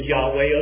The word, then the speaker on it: Yahweh: the word that's in the Hebrew that's being translated is Jehovah, or maybe more appropriately pronounced in Hebrew, Yahweh.